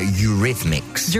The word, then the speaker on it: Eurythmics: Eurythmics, We Dreams är äntligen lördag i Mix Megapol. Det hjälper om du säger det rätt, det är Eurythmics. – Eurythmics. – Eurythmics, not Eurythmics.